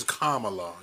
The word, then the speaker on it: Kamala: Yeah, Carmella. She says Kamala. – Is it Carmella? Okay. – Kamala.